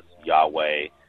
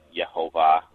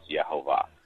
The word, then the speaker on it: Yehovah: It's Yod Heh Vav Heh, pronounced by people, various people, as Yahweh, Yehovah, or Jehovah. And, you know, but the closest to what most people think it was originally pronounced was Yehovah, because there is no J sound in Hebrew.